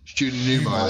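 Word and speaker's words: Julie Newmar: The woman on the way, if you go back up, Phil, Julia Neymar. That's Catwoman at the Batman. – Judy Newmar. – That's how you say it, sorry.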